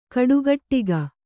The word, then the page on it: ಕಟುಗೊಡಗಿ: kannada